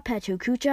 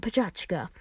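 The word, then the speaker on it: Pecha Kucha: In fact, this event started in Japan, and pachakacha literally means chit chat in Japanese. I say pachakacha differently than many other people. Pachakacha can be pronounced pachakacha, pachukucha, and pachachka.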